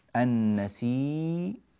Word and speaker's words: النسي: ياء واحدة مشددة مضمومة، يعني هذه الياء خلاص زالت وصار النطق عندي بياء واحدة مشددة مضمومة، النسي، النسي، ها هي أمامنا، النسي، النسي، هكذا يقرأها الإمام ورش رحمه الله.